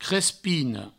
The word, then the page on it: crespina: crespino